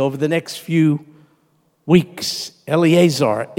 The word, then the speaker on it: Eleazar: Lazarus's name is Lazarus in Greek, but in Hebrew, it's Eleazar. Eleazar, which means God is my help.